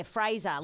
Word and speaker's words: Fraser: Yeah, Fraser, like razor.